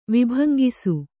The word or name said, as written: ವಿಭಂಗಿಸು